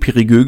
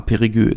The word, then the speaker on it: Périgueux: Und ja, das spricht man tatsächlich so aus. Ich war auch überrascht. War in, der letzten, in den letzten Folgen nicht so ganz sicher, aber ja, Périgueux ist richtig.